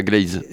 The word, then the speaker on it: Glaise: On prononce comme ça votre nom Oui, c'est ça, Glaise.